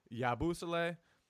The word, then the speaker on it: Yabusele: Yabusele, however you say his name, I still can't get that. – No, you nailed it what. that time. You got it.